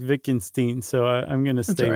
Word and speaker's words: Wittgenstein: And if you don't, then you're just shooting yourself in the foot, essentially, was what Ludwig Wittgenstein was saying. – Yeah, I think, unfortunately, Wittgenstein. Well, I didn't see it, but I think the second letter in the EI in German is the one that's favored.